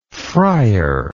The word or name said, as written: frier